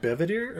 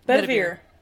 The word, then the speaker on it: Bedivere: Oh, Bevedere or Bedivere? – Bedivere. – Okay, that sounds too close to Guinevere, but I don't care.